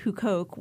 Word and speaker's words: Huqoq: It's at a site called Hukoke, which is spelled H-U-Q-O-Q.